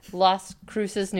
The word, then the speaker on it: Las Cruces: I want to, the distance from Roswell, New Mexico to, is it Santa Cruz? Where's the Las Cruces? Okay, I did not say that right, but Las Cruces, New Mexico, which is where, so here's the thing.